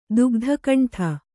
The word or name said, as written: ದುಗ್ಧಕಂಠ